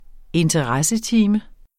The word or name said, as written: interessetime